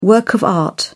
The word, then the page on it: work of art: British English